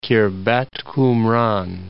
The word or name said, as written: Khirbet Qumran